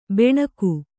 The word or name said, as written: ಬಣಗ